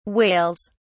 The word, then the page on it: whales: British English